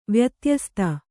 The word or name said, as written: ವ್ಯತ್ಯಸ್ತ